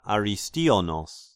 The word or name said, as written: Aristionos